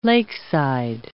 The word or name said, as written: lakeside